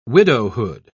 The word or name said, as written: widowhood